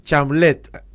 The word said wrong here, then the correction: Chalamet: algo así se pronuncia. – Pero sí. Muy famoso. – Es muy famoso, es como el actor de... – Chalamet. – Ahí está, Chalamet.